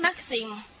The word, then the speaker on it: maxim: 你看它的发音 maxim。好，有点像马克思的感觉，像不像？